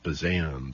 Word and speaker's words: Buzan: The author of Mind Mapping, Tony Buzan, or Buzan. I think he pronounces it Buzan, B-U-Z-A-N, Tony Buzan.